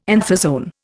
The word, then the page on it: anthozoan: Indian